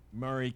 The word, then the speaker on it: Maury: Um, Columbia, the county is Maury County. Maury County. Maury County. – M A U R Y. Correct. Yeah. Just think of mm-hmm. Maury Povich.